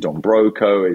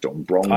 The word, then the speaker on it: Don Broco: Is it Dombroco? Is it Dombroco? A lot of people add an N in there, so it's Dombronco.